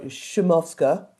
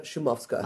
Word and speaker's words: Szumowska: And so the SZ part is pronounced Sz, so Szumowska. Małgorzata Szumowska.